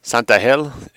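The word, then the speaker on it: Santángel: Luis de Santangel, Santangel, S-A-N-T-A-G-E-L, G is pronounced H in Spanish,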